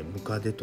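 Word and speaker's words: 百足: 100 本の足と書いてムカデと読む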